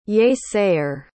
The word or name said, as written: yea-sayer